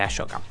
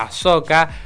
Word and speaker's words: Ahsoka: En Ashoka, ah, que no se dice Ayoka, se dice Ahsoka, pero yo todavía le dije Ashoka.